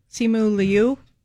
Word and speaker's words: Simu Liu: Simu Liu is how you just say it? – I think so. Simu Liu.